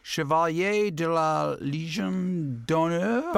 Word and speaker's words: Chevalier de la Légion d'honneur: He's even gotten the highest honor you can get in France, and correct my pronunciation here, Chevalier de la Légion d'honneur. – Perfect. Parfait. Ooh-wee.